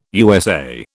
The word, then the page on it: USA: British English